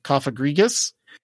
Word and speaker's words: Cofagrigus: Was it Cofagrigus? – Yeah, you said it right.